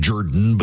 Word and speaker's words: Jordan: So two Democrat progressives ran for office, Miracle Rankin and Jen Jordan, who pronounces her last name Jordan because she's from south of Atlanta and that's what they do.